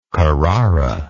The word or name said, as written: carraran